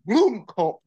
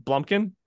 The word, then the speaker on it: Blomkamp: I said his name wrong. It's Blomkamp, Blomkamp. – It's not Blumpkin. – No, it's Blomkamp.